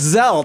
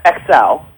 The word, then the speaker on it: Xelle: Yeah, well, it's actually pronounced XL. – XL, okay. XL, like Microsoft. – Yes, it is, and it's spelled X-E-L-L-E, which mm-hmm.